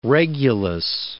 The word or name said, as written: regulus